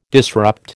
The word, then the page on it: disrupt: American English